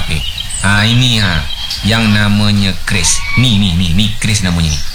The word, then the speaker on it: QRIS: Kris, Bu. Kris. Q-R-I-S. Dibacanya kris, bukan keris.